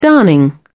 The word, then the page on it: darning: British English